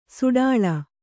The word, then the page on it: ಸಡಾಳ: saḍāḷa